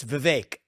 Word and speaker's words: Vivek: First of all, am I saying Vivek? Is that how you say it? – It's pretty close. It's Vivek, like cake.